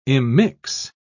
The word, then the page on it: immix: British English